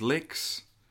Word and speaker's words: Lex: I remember the guy that came in, he's like, hey, my name's Lex, rhymes with sex, and he'd have us, that was the first line he had of right us right from the get-go.